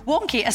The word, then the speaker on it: Wonkhe: Mark is the founder of Wonky. Am I pronouncing it correct? Good. Wonky, a service that describes itself as the home of the UK higher education debate.